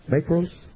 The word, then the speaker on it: makros: It comes from two Greek words, makros, M-A-K-R-O-S.